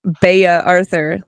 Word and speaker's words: Bea Arthur: Wondering where this person may originate from, that it is Bea Arthur.